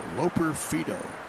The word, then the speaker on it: Loperfido: Loperfido, L-O-P-E-R, and then F-I-D-O, like Fido. But pronounced Fido, Loperfido.